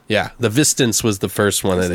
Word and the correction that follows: The Visitants: Did I say it wrong? Thought it was The Visitants. Maybe I'm retarded. Who knows? – Oh, you're right. The Visitants. I don't know why I said it that way.